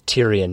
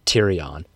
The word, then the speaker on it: Tyrion: Well, pronounce it correctly, please. It's Tyrion, not Tyrion.